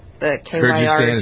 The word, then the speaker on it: Kyrgyzstan: So the president's travel ban covering six more countries, Eritrea, Myanmar, Nigeria, Sudan, Tanzania, and a country I can't pronounce, Kyrgyz, I don't even know where this is. It's got to be one of the stands like Afghanistan. Anyway. – Kyrgyzstan? Kyrgyzstan is how you pronounce it? – Yeah, or whatever. Yeah, close. Good, thank you.